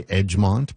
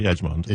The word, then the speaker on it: Egmont: So just Lake, and I hope I say it right, Egmont, E-G-M-O-N-T. I want to say Edgemont. – I say Edgemont. – Is it Edgemont? – Well, that's what I say. I could be wrong.